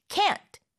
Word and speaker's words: can't: 발음할 때 모음을 약간 길게 해서 강하게 can't, can't라고 하고요.